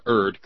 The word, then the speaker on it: erred: You're the first and only person in the last 20 years I've heard pronounce the word erred correctly. E-R-R-E-D.